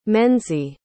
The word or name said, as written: menzie